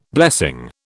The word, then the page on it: blessing: British English